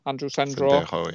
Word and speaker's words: Sendejo: Dwelly against, I think I'm going to pronounce his name, their safety will be Sendro. – Sendejo, yeah.